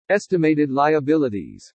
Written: ESTIMATED LIABILITIES

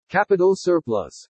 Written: CAPITAL SURPLUS

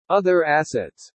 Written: OTHER ASSETS

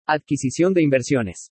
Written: ADQUISICION DE INVERSIONES

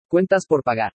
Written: CUENTAS POR PAGAR

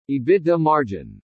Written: EBITDA MARGIN

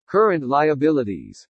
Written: CURRENT LIABILITIES

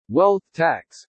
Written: WEALTH TAX

